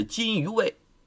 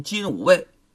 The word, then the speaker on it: 金吾卫: 左右后卫啊，就是金鱼卫。金鱼卫很多人呢都读成金吾卫，其实应该叫金鱼卫，负责皇帝出行的时候前驱后殿，日夜巡查，止宿实施警戒之责呀。